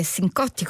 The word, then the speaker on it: Cincotti: C'erano vari ospiti, tra cui Dado Moroni, Peter Cincotti, o forse Sincotti come lo pronunciano negli Stati Uniti,